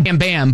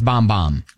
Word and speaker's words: Bam Bam: Well, yes, it looks like Bam Bam, but it's pronounced Bomb Bomb.